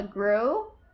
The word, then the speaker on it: Grue: And this past weekend, I was actually at what's called a GRU. It's spelled GRUE.